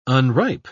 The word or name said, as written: unripe